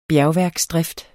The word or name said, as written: bjergværksdrift